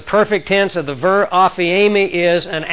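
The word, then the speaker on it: aphiemi (ἀφίημι): But the word forgiven here, your sins are forgiven for His sake, it's the word aphiemi, aphiemi, it's a long E.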